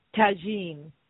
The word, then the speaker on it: tajine: Tajine.